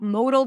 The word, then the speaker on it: modal: Especially if you learn English, you learn about modal verbs. Mo, right? It looks the same. The only difference is E versus A, but these are two different words pronounced differently. Model, modal. Model, modal.